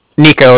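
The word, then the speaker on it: Niko: Nico, that's N-I-K-O. And I'm calling with the Bank of Cardiff, and that's spelled C-A-R-D-I-F-F, as in Frank, so it's like car and then different.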